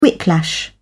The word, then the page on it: whiplash: British English